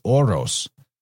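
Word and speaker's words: oros (ὅρος): So that would be omicron rho omicron sigma, O-R-O-S. But that's phonetically pretty much the same word as the aura, oros, oros. It's like the word, the same phonetically, you're almost saying the exact same thing as the spirit or the divine wind or the breath, which I find fascinating.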